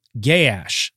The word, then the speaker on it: Gaash: We've got Hadai of the ravines of Gaash. This is spelled G-A-A-S-H. It is pronounced Gaash. However, I have seen it pronounced Gaash as well. So however you like to pronounce that, you can.